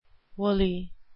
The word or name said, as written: woolly